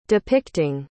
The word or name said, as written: depicting